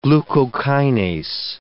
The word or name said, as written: glucokinase